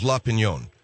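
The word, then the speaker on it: La Opinión: I got some people who were mad at me when it was printed in La, in La Opinion, Well, the pronunciation is La Pinion.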